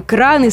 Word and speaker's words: краны: Поэтому, вне зависимости от числа и падежной формы, ударение всегда и везде будет падать на первый слог. А значит, будет множество кранов, купить новые краны, строительные краны, использовать пожарные краны и так далее.